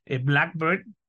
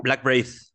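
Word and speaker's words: Blackbraid: Digo, con exponentes de muy buena calidad, como este Blackbird. ¿Cómo lo pronunciaste? ¿Blackbird o Black Braid? ¿Cómo? – Black Braid. Black Braid.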